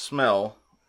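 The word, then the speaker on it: smäll: The Swedish word for small, or S-M-A-L-L. Which is pronounced smell, is the Swedish word for crash.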